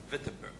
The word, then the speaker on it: Wittenberg: Martin Luther nailed his 95 theses, or 95 points of theological debates, on the door of University of Wittenberg in Germany, or Wittenberg, as the German would pronounce it.